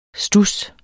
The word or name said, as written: studs